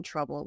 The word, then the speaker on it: trouble: The T in trouble sounds like a ch, and then the O is silent. So don't say in trouble, in tra. In trouble, bull. Whenever a word, usually when a word ends in L-E or like B-L-E, just flip the E and the L to make the right sound.